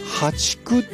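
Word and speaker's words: 淡竹: でこれからはね、ハチクっていうね、淡い竹と書いてね、淡いはあの淡水魚の淡ですね、淡い竹と書いてハチク、そして、今月末にはマダケといった種類が芽を出してくるということなんですね。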